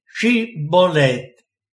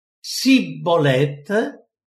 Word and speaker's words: Shibboleth: di Shibboleth». E se quello diceva Sibboleth, non riuscendo a pronunciare bene, allora lo afferravano e lo uccidevano presso i guadi del Giordano.